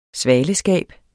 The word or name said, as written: svaleskab